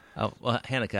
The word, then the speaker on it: Haneke: Haneke, I meant Abbas Kiarostomy.